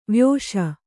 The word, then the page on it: ವಯಸ್ಸು: vayassu